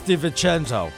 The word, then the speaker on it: DiVincenzo: It's DiVincenzo.